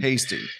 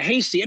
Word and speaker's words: Heastie: Hasty. – Yeah, Carl. Yeah, Hasty.